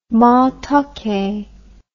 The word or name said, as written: matoke